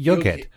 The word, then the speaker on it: Jughead: No, I think it would be Jughead. – Yeah. – Like Jawohl. Jawohl is spelled with a J, so Jughead would be Jughead.